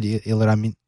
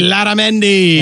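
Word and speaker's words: Illarramendi: So they had Ilaramendi, I think is the way you pronounce it. – Ilaramendi.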